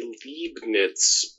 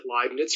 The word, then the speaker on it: Leibniz: Some people say Liebniz, but it's German, and in German it's really easy to figure out, because if you're pronouncing it the German way, and in the United States they berg the berg and all those things, but in German, the way that if you're doing pronouncing it the European way, the last letter is what you say.